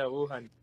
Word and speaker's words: Wuhan: Yeah, Wuhan.